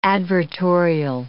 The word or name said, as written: advertorial